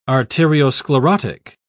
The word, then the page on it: arteriosclerotic: British English